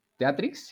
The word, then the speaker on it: Teatrix: Teatrix, sí lo pronuncié bien porque. Sí, Teatrix. O sea, como. Teatrix. – T-E-A-T-R. – Exacto. Como Netflix, pero de teatro.